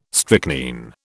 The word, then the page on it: strychnine: British English